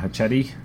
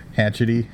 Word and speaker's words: Hachette: Pronounce it like that movie, the Machete. – Machete. – Yeah, which is with an H, just in a very Hispanic Hachette.